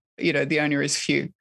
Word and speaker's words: fiu: Like I write very briefly in the book about this Tahitian idea of phew, F-I-U, and I love that it's pronounced phew as well,